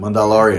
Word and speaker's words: Mandalorian: não sei se tu lembra, que a gente conversou, que quando eles lançaram lançaram, eles lançaram a série do Star Wars, The Mandalor... Mandalor... Man, eu não sei pronunciar. – Mandalorian. Mandalorian, isso.